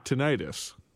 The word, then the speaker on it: tinnitus: I have tinnitus. – Is that what you say when you've got ringing in your ears? – Yes. – How do you say it? – Tinnitus.